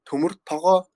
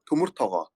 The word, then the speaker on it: Tumurtogoo: So you, um, the Mongolian pronunciation would be Tumurtaga, Tumurtaga.